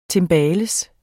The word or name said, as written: timbales